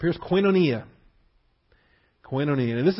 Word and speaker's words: koinonia: Koinonia. K-O-I-N-O. It's a long O. N-I-A. Koinonia.